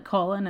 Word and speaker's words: Colin: Other bodily names include Colin or colon, I guess, especially when it's pronounced like colon as in Paul.